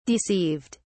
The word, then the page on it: deceived: British English